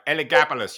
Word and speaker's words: Elagabalus: Yeah, and I got to pronounce it right, Elagabalus.